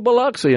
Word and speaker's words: Biloxi: they did properly pronounce Biloxi. Because, you know, it's a word that's often pronounced Biloxi. You hear that. If you don't know, you know, and you're from our state, but somebody had coached him on that. So it was really good when the lead guitarist, kind of main figurehead of the band, Neil Sean, he said, hello, Biloxi. I mean, he said it right.